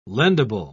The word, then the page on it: lendable: British English